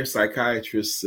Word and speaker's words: psychiatrists: How come you can't hear psychiatrists in the bathroom? – How come? – Because the P is silent.